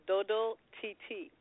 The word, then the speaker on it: Dodo TT: So it's actually pronounced Dodo TT, but it's supposed to have the T at the end for TT, because okay. – Dodo means it's a Creole for Haitian Creole for sleep, and TT is little one. So it's a Haitian lullaby, and that's pronounced Dodo TT.